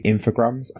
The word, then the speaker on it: Infogrames: A couple years later, in 1999, they were bought by Infogrames. I think I'm pronouncing that correctly. – Yep. – Okay, good.